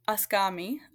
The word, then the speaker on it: Eskami: Did I pronounce that correctly? – Eskami. Uh-huh. Eskami Homestead.